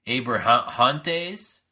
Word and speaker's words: Abrahantes: On the level of awkward, how awkward was it for you to see Alex Abrahane? I can't even pronunciate his last name. Abrahantes? Yeah, there we go.